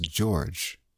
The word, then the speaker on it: Gyorgy: Now she spells her name C A I T Y G Y O R G Y, but it's pronounced George, as we'll find out for sure as we go on.